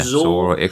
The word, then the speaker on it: Xor: Yoldi Indie Guy has suggested Zor. I think that's how you pronounce it. X-O-R. – Yeah, Zor.